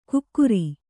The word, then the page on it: ಕುಕ್ಕುರಿ: kukkuri